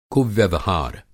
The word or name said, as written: कुव्यवहार